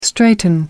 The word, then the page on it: straighten up: American English